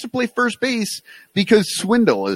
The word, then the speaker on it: Schwindel: What I don't understand, though, is so Schwindel, which Sugar says it's Swindle. And if anybody knows about mispronouncing a name with an extra sh in it, it's Bill Sugas. – Yeah, yeah, because it's really Sugas.